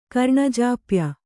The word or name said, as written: ಕರ್ಣಜಾಪ್ಯ